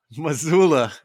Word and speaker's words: Mazzulla: It's Joe Mazzulla. – Mazzulla. It sounds like a city in Montana. – He's got that Italian, baby. He's that Italian. – Mazzulla.